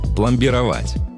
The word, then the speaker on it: пломбировать: Одно из них – пломбировать. Да, ударение в нем ставится именно так и никак иначе.